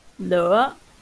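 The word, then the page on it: lửa: Vietnamese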